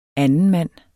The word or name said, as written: andenmand